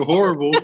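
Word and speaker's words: Horbal: It's H-O-R-B-A-L, and that's how he pronounced it. I was like, yo, you could just pronounce it Horrible. Like, you know, who would ever go see Dr. Horrible before the dentist?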